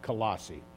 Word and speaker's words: Colossae: Another word that people have trouble pronouncing, that's the way I'm going to pronounce it, Colossae.